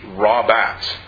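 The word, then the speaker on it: Rabats (רבץ): The Hebrew word here for lies is Strong's 7257, and it's pronounced Rabats, R-A-B-A-T-S. And if Hannah was on the line, we'd say for, as opposed to cooked bats, because she loves bats.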